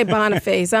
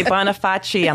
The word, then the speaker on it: Boniface: We say Boniface. I'm from the South. We'll drag out that last syllable. Boniface, ambulance. – I say Bonifaci.